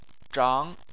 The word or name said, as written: zhang2